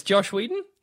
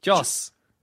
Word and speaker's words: Joss Whedon: What is Josh Whedon? – Joss.